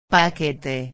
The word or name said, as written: paquete